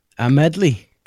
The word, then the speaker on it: Amedly: A-M-E-D-L-Y. How would you pronounce that? – I don't know. Amedley.